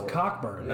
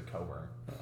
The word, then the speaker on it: Cockburn: I thought it was Cockburn. – No, that's how it's spelled, but it's like he's from like Jamaica, so they say Coburn.